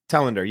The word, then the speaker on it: Telander: Telander.